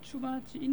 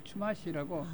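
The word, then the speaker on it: Chumash: 추마치 인디안이 살았어요. 추마시라고. – 아, 추마시 인디안. – 우리말로 발음을 하면은 추마치 좀 되기도 하고 추마시라고도 되기도 하는데 그 추마시 인디안이 살았는데 그 인디안들이 원주민들이 원래 살았는데 이 사람들이 많이 살았어요.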